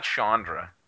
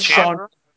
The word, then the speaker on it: Chandra: You also say Chandra, and it's not Chandra. – It's Chandra. – I was going to let that slide, but... – Chandra. – Like Chandra. – It's Chandra, with an S.